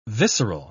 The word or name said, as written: visceral